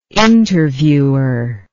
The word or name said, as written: interviewer